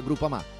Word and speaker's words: Groupama: Groupama, ah no, si pronuncia Groupama, di Paolo Felicetti.